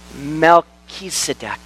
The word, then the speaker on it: Melchizedek: You want to pronounce rightly this one, though, because he's the only king that matters. Melchizedek.